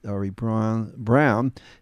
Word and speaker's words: Braun: Ari Braun, B-R-A-U-N, but they pronounced it Ari Brown.